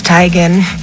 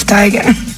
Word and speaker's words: Teigen: I'm tired of living this lie. It's Teigen. Isn't it, Mom? – Yep. – I told you, it's Teigen.